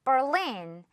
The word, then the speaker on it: Berlin: 자, 그런데 영어 발음은요, Berlin, 이렇게 눈에 보이죠? 이대로 해주면 돼요. Berlin, Berlin, okay? Berlin, not Berlin, Berlin, 이건 이제 잊어요.